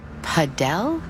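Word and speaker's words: padel: Wait, is that Paddle? – No, that's paddle. Rhymes with saddle.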